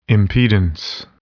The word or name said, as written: impedance